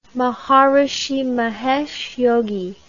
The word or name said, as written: Maharishi Mahesh Yogi